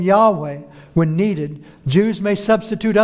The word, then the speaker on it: Yahweh: And it is commonly pronounced, and I'm sure I won't be like a full Jew in saying this, but something with the way of Yahweh. Yahweh.